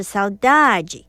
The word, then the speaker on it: saudade: 如果你是读巴西葡语啊，常用的发音听起来像是 saudade。如果你是用欧洲的葡语，也就是葡萄牙文的葡语，听起来比较像是 saudade。